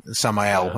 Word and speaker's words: Samael: Hellboy and Abe go and obviously come across the, is it Samuel? No, how do you pronounce it? – Samael. – That's it.